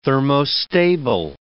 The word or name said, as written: thermostable